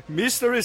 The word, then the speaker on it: Mystery: Eu sou, como, é que, como é que é a pronúncia certa aí? Mectoshana. Mystery Science 3000.